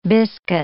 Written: biscuit

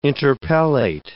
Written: interpellate

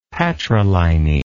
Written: patriliny

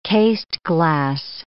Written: cased glass

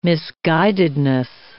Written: misguidedness

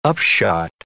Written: upshot

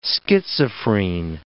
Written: schizophrene

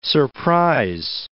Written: surprise

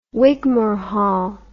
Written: Wigmore Hall

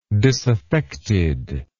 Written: disaffected

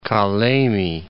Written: Kalemie

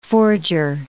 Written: forger

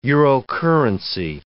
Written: Eurocurrency

